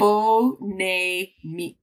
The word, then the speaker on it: Phonémique: Phonémique. Did I say it right? – Phonémique.